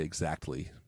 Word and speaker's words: exactly: No, I have to like I've trained myself to say it in a way that is unnatural so that I can get the T in there. So I say exactly, but...